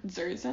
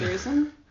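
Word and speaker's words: Dzierzon: It's D-Z-I-E-R-Z-O-N, Zerzan. – I'm not going to try it. – Zerzan.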